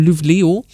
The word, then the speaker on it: Luleå: die wird geschrieben Luleå, wird ausgesprochen Luleå und da sind es tatsächlich sternklar in der Nacht, sonnenklar am Tag, minus 7 Grad und jede Menge Schnee.